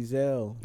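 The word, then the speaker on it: Ezal: But other than that, we can talk about Crackhead Eagle Easel's death. – Crackhead Ezel. – Ezel. – Ezel. – Always. When I was a kid, I was. – You never watched the fucking movie? – I've watched the movie. – They say his name like 20 times. It's Ezel. – I didn't give a fuck as a kid. – And I'm telling you now, it's Ezel.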